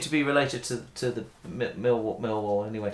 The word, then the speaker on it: Millwall: But I'm pleased to hear that you pronounced it as Millwall and not Millwall, because quite a few people seem to, especially people who support Millwall, seem to put the emphasis on the wall bit rather than the mill bit.